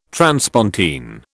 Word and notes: transpontine: British English

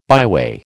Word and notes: byway: American English

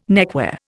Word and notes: neckwear: Indian

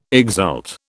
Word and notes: exult: British English

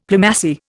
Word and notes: Plumassier: Indian

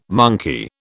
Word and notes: monkey: British English

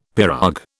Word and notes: pirogue: British English

